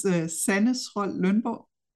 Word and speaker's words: Sanne Schrøl Lundborg: My name is Sanne Schrøl Lundborg. I'm Danish, so that's why it's pretty weird to pronounce in English.